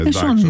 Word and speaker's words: Sean: Sean είναι η προφορά, τι να κάνουμε. – Συν εγώ το μέσα. Το λέγαμε λάθος εμείς. – Έτσι μεγάλωσα. Ναι. Συν τον ήξερα τώρα.